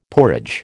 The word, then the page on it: porridge: American English